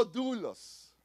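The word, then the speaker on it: dulos (δοῦλος): Ahora, la palabra que describe esclavos, y buscamos en, en, el griego original, es la palabra dulos. Di conmigo dulos, como si fuera doble O, así más o menos se pronuncia, y quiere decir que es un esclavo.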